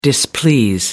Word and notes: displease: American English

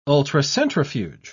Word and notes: ultracentrifuge: British English

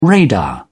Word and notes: radar: British English

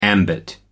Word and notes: ambit: American English

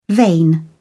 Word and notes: vein: British English

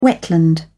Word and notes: wetland: British English

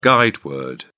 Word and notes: guide word: British English

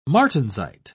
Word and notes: martensite: British English

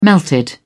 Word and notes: melted: British English

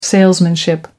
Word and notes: salesmanship: American English

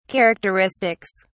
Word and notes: characteristics: British English

